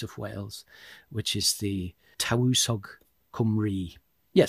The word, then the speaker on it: Tywysog: So these leaders in Welsh, it's spelt T-Y-W-Y-S-O-G, in the single, and then I think the plural, there's an I-O-N on the end. My Welsh pronunciation is not good, so apologies if I get it wrong, but I think the word for these leaders was Tawusog in the single and Tawusogion in the plural, which in the sort of official documents of the time was translated into Latin as Principes, which becomes anglicised as Prince.